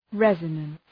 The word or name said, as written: resonance